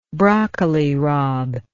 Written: broccoli rabe

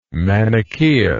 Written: manicheus

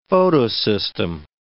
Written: photosystem

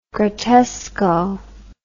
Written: grottesco

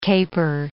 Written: caper

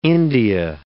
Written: India

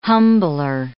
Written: humbler

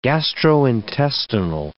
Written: gastrointestinal

